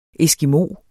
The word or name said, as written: ESKIMOER